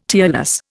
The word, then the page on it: tearless: Indian